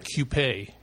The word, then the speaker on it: Coupe: By the way, I pronounce it Coupe. – Coupe. – Like, yeah, like K-Y-O-O-P-A-Y.